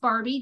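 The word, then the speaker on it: Barby: And, it's pronounced Barbie, just like the doll, but it's spelled different.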